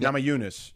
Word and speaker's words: Namajunas: Rose Namajunas, is that how you say it? – Namajunas, yeah.